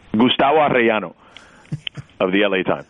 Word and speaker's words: Gustavo Arellano: Gustavo Arellano wrote a story about his impact in the Latino community. – You pronounced the hell out of that, my friend.